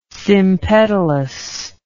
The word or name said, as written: sympetalous